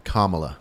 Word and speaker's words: Kamala: Like, because you know, Kam- let me let me correct you, Kamala. – Kamala, Ka- Kamala. – Right. The emphasis is on the Ka.